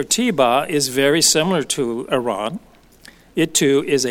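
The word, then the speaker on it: Aaron (ארון): In the Hebrew, the word ark in the Ark of the Covenant is the word Aaron, and that's spelled A-R-O-N, which is Strong's number 727, and it means ark, chest, or coffin.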